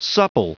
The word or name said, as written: supple